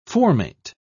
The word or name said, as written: formate